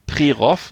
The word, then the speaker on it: Prerow: Und da kann man da gibt es einen Campingplatz, der heißt das der Regenbogen Regenbogencamping Prerow, geschrieben für Westdeutsche Prerow mit OW am Ende. Wird aber O ausgesprochen, so wie die ganzen Orte.